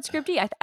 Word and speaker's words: Scribd: Or it should, not Scribd, no, Scribd, how do you say it? I don't know how you say that one. Scribd-y. – It's Scribd-y.